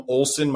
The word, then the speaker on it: Olson: Follow us on Twitter at FBAOT1, or you can find me on Facebook, Adam Olson.